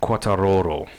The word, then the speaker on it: Quartararo: The biggest thing that came out of this race is an emerging young star, Fabio Quartararo. – Very good. I think that's about the fourth or the fifth time you've pronounced it spot on the show.